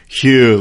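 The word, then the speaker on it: Huw: He's like, dude, the Welsh name Hugh is pronounced Hugh, like the word few.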